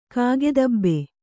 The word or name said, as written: ಕಾಗೆದಬ್ಬೆ